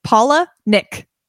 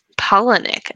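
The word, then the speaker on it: Palahniuk: He says it's pronounced Paula Nick. – Oh. – Polonick.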